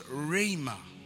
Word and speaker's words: rhema: The second word for word is the word rhema. Pronounce it, say rhema. Okay. Not a rhema.